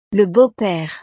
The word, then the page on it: le beau pere: frances